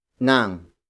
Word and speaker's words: ng: The next word is nang. Nang. Nang. Nang is spelled as NG.